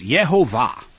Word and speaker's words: Yehovah: But regardless, he concludes that the proper pronunciation, and this is the proper rabbinic pronunciation, is Yehovah.